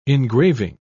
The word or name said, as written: engraving